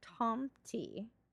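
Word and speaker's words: tomte: Nisa or Tom T, I believe is the pronunciation. I tried to write it out phonetically. It's known as Nisa in Norwegian and Danish and Tomti in Swedish and Finnish.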